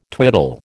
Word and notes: twiddle: American English

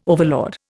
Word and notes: overlord: Indian

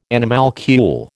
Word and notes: animalcule: American English

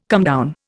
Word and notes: comedown: Indian

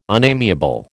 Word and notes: unamiable: American English

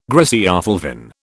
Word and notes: griseofulvin: British English

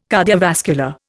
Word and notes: cardiovascular: Indian

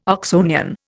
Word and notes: Oxonian: Indian

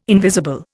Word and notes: invisible: Indian

